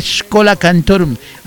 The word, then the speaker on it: Schola Cantorum: Siento tu llamada de Schola Cantorum. Así se pronuncia. Se escribe Schola.